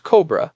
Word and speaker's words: COBR: The acronym is usually pronounced COBRA, and it's located in the Cabinet Office building on Whitehall.